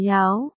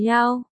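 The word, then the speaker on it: Yao (瑶): Tên em người hầu thế này, Yao là ngọc, đẹp, đọc là Yao. Tiểu Ngôn nghe thành từ này, Yao nghĩa là yêu quái. Cả hai đều đọc là Yao.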